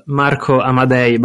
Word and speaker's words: Marco Amadei: The correct pronunciation is Marco Amadei, but yeah, of course, can be a little bit tricky to pronounce for non-Italian, so don't worry.